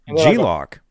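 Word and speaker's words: G-LOC: Abbreviated as G lock, pronounced G lock.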